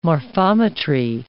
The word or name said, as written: morphometry